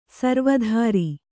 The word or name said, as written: ಸರ್ವತ್ರ